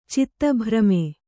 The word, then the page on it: ಚಿತ್ತಭ್ರಮೆ: citta bhrame